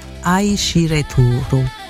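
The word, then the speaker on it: Aishireturu (愛してる): Siamo in giapponese, si dice, si pronuncia Aishireturu. – Aishireturu? – Turu, sì.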